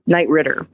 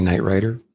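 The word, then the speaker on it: Knight Ridder: I think a lot of people make the mistake of saying Knight Rider. – Like the show. – Yeah, but that's wrong. That's wrong.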